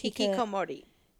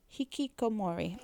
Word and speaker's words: Hikikomori: I'm not sure, how is this pronounced? – Hikikomori. – Hikikomori. Yeah. Okay, there we go.